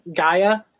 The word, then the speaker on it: Gaea: It's uh, spelled G A E A Gaia uh, Gaea Girls.